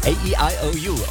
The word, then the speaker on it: AEIOU: Aussprechen kann ich sie nicht, wird geschrieben A-E-I-O-U, also wie die Vokale. A-E-I-O-U. Okay, ich kann sie doch aussprechen. A-E-I-O-U, Oliver Heldens, um kurz nach 11:30.